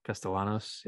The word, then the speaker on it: Castellanos: Castellanos, yeah.